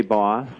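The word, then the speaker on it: Bos: Boss. – Boss, and it's B-O-S. It is not B-O-S-S.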